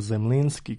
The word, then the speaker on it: Zemlinski: Aleksandar von Zemlinski ili Aleksandar Zemlinski, kako se ovde često izgovara prezime ovog kompozitora, iza sebe je ostavio neveliki ali raznovrstan opus.